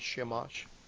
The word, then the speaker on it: Shamash: Shamash? – Yes. – Shamash.